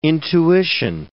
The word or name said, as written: intuition